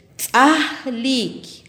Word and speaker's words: tzahlik: Banano en pocomá le decimos tzahlik, tzahlik. Repitamos tzahlik.